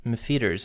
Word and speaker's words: M'Pheeters: Mefeaters. Mefeaters, and it's spelled M'Pheeters. – Yeah. – Which we think is pronounced Mefeaters, but... – It looks like Mefeaters.